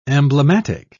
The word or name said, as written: emblematic